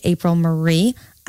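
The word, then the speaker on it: Apryl Marie: One, you can find me online at APRYLMARIE, April Marie. I say my name so fast. It's a double first name.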